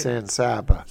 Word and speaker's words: San Saba: I will be there Friday in Kyle against San Saba. Is that how you say it? – San Saba. – San Saba.